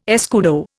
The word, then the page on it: escudo: Indian